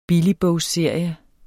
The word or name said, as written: billigbogsserie